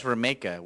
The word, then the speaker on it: Romica: It's actually pronounced Romica, which he never corrects anybody on. – Really? – It's spelled the way you're saying it. It's pronounced, it rhymes with Jamaica.